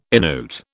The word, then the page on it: Innuit: British English